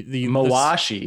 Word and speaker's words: mawashi: Yeah, that it's like, you know, same thing with the Miyashi. I'm sorry for mispronouncing the Mawashi.